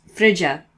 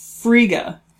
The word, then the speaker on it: Frigga: It was pronounced Frigia, I believe, in the movie. – I don't think so. – How was it pronounced? – I think it was Frigga. – Frigga? – Yeah. – Okay, fine. Frigga.